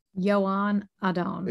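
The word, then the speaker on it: Joan Adon: Yoan Adon. Did I get it right? – Yoan Adon. – Is it Yoan? – Okay. Yoan, I think. Or Yoan Adon.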